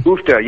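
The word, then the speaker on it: Uff da: So, and is that, are we pronouncing that right? – Oofta, yeah.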